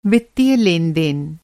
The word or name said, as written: लेन-देन